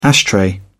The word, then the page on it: ashtray: British English